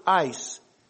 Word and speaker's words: eis: He uses the term ice, little Greek word ice. If you transliterate it, it's spelled E-I-S.